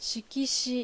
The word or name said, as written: Shikishi